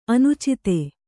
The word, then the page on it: ಅನುಚಿತೆ: anucite